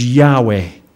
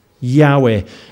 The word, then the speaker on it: Yahweh: And the most accurate pronunciation, the nearest we can get, is Yahweh. Yahweh.